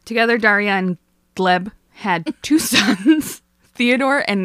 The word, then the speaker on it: Gleb: who I can't decide if his name is Gleb or Gleeb. It's spelled G-L-E-B. I'm going to call him Gleb.